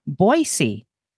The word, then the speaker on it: Boise: specifically from outside Boston to Boise.